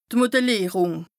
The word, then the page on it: d' Modellìerùng: Bas Rhin